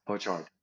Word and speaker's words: Orchard: The Orchid. Orchard. ok, d'accord. Donc, je l'ai mal prononcé.